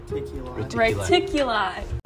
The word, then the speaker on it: Reticuli: Reticuli.